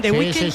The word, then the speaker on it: The Weeknd: Miguel Ángel, lo he dicho bien, The Weeknd.